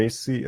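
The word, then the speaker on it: Raci: Paul Racy, Reci, biztos így ejtik, bocsánat, Racy. – Racy, és Licky Stanfield, Julius and the Black Messiah.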